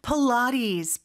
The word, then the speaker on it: Pilates: Pilates. 필라테스인데 발음이 참 독특하죠, 그죠? 필라테스라고 하면은 사실 원어민들은 못 알아들을 수 있을 것 같아요. – Yeah. – Pilates. 이렇게 발음을 합니다.